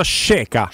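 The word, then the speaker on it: Xeka: confermi che si pronuncia Sheca.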